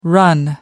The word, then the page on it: run out: American English